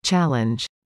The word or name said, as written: challenge